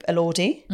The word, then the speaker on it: Elordi: We've got long, gorgeous Jacob Elordi.